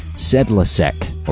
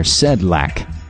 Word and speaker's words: Sedlacek: Originally from the Bellevue News-Democrat, Terry Sedlacek, and I've heard it pronounced some different ways, Sedlacek or Sedlak, S-E-D-L-A-C-E-K.